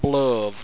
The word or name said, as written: blove